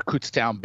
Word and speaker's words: Kutztown: Yeah, I mean, I'm inclined to be bullish about the former Kutztown Bear.